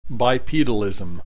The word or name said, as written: bipedalism